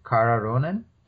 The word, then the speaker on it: Kara Ronin: Is it Kara Ronin? – That's exactly right, yes.